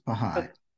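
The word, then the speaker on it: Bahá'í: Is it Baha'i? – Yes, Baha'i. Okay.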